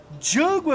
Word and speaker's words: Django: Django. – The D is silent. Então, eu lhe pergunto, meu amigo, em ritmo de filmou, qual é a sua nota para Django Unchained, ou melhor, Django Livre?